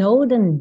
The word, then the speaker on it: olden: Porque esse E não é pronunciado, você não fala olden e nem golden, é olden, golden, dn, É só o necessário, assim, é bem nasal esse som.